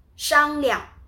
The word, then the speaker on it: 商量: 商量 discuss 商量 discuss. The 量 when you pronounce it kind of like uh, unstressed. So close to neutral tone.